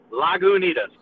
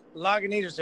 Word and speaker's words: Lagunitas: So give us the official way of pronouncing it. – All right, so you got La Goo Tus. Lagunitas. – Lagunitas,